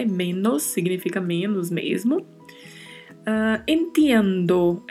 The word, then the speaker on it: entiendo: Particularidade de pronúncia aí, este TI, né? Entiendo. Como já mencionamos em outros podcasts, a pronúncia é ti e não ti. Então falamos entiendo.